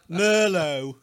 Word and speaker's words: Merlot: I mean, it's spelled Merlot without the T. Merlot.